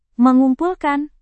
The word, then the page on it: mengumpulkan: Indonesian